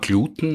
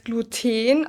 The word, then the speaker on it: Gluten: Im Duden steht, soweit ich weiß, Gluten, aus dem Grund, dass sehr viele wissenschaftliche Begriffe auf diese betonte letzte Silbe enden. Aber wenn man es sprachhistorisch anschaut, dann wäre es Gluten, weil auf Latein war die Betonung auf der U-Silbe.